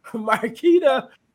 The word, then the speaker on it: Markita: Markita.